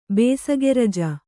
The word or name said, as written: ಬೇಸಿಗೆರಜ